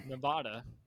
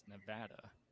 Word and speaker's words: Nevada: quickly I was corrected. No, it is Nevada. – Mm. So ever since then, I've pronounced it Nevada because that's the way that people in Nevada pronounce it.